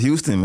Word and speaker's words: Huston: Wait, Huston or Huston? – Huston. – No, it's Huston, man. Huston, everybody say my name wrong. It's Huston.